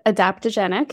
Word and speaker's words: adaptogenic: So she partnered with a team of dietary experts and chemists to develop the most effective and powerful aptogenetic. Did I say that right? – Adaptogenic. – Yeah. Adaptogenic blend.